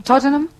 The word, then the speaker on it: Tottenham: Tottenham.